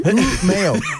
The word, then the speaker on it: Umeå: Give us that pronunciation again. – Umeå. – Wonderful. – U-M-E and then A with two little degrees symbols on top of it.